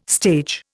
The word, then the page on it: stage: Indian